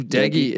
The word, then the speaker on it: Doege: And really, when you look at West Virginia, to me, if it's Jared, and I always thought it was Doge, their starting quarterback, he's a Bowling Green transfer, but I believe it's Jared Deggy. I believe Deggy is the correct way to pronounce that.